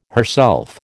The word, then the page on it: herself: American English